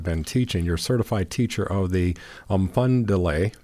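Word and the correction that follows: Umfundalai: Mufundala. – Mufundala. – Yep. – Okay. – It's deceiving because it starts with a U, but you first mentioned the M in pronouncing it, okay.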